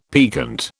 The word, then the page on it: piquant: British English